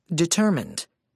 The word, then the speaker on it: determined: Determined. 3 syllables, main stress on the second syllable.